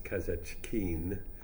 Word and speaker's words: Kazikin: It's not an easy name. It's a Russian name. However, when my parents immigrated from Russia to Europe, to Western Europe, they immigrated to France. That's why the name is pronounced Kazikin. It's a sort of French way of pronouncing it, although it's a Russian name.